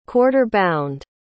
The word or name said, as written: quarter-bound